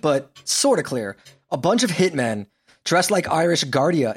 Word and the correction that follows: Garda: It's Garda. I mean, Garda. Just you can just say guards.